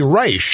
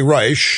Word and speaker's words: Reich: Now, if you are new to the program, you might be wondering, why did I pronounce his name that way? Well, Secretary Reich used to be a regular commentator on the NewsHour, the McNeil-Lehrer NewsHour, back when McNeil was on it. And he'd do his little commentaries, and at the end of his commentaries, he would go, I am Robert B. Reich. And trail off. He doesn't pronounce his name that way. This is just a TV gimmick.